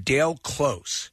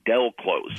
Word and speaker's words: Del Close: A gentleman named Dale Close. – That's from the UK Vice interview. They say his name wrong. Del Close.